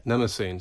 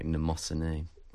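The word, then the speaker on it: Mnemosyne: Nemesine, so you don't have to pronounce the M, do you? No. Um, there's well, there's a story behind that. We I say Nemesine in the song, but um, I believe that the Greek pronunciation is something more like Nemosine. – Right. Okay. okay. But uh, the English people just say Nemesine, I presume.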